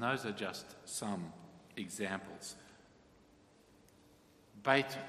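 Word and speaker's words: Beit (בית): The Hebrew word Beth, or more properly pronounced Beit, means house.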